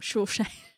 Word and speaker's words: Saoirse: Whereas, I can't even say her name, Saoirse.